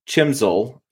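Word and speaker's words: CHMSL: And then I couldn't turn on the chisel lamp. Chimsel is the right way to say it.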